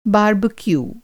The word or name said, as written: बारबेक्यू